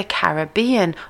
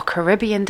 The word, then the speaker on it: Caribbean: Columbus called this native tribe Carib, and over the years the name for the region evolved to be the Caribbean, or Caribbean, depending how you pronounce it and where you're from.